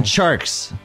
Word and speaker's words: Tjarks: You're not saying it right. Jonathan, I wasn't going to say anything. – Jonathan Tjarks. – There we go. – It's like a CH. – It's like a CH, yes.